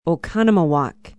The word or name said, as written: Oconomowoc